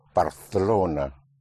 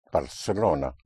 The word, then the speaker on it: Barcelona: There's a slight difference in pronunciation with so-called Castilian Spanish in the pronunciation in parts of Spain, where the C in front of an E or I is pronounced like a TH in English, like in Barcelona. Well, that's how you will pronounce it in Castilian Spanish and parts of Spain, but everywhere else it will be pronounced Barcelona, like an S.